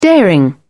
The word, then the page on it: daring: British English